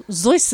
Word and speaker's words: Seuss: So we have a brilliant quote from Dr. Seuss, or Zeus, as I was told. It's actually pronounced by a German friend of mine.